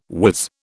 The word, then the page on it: wiz: British English